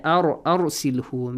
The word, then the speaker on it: أرسله: In the next ayah, أرسلهو, make sure you pronounce را with a heavy sound. تفخيم الراء.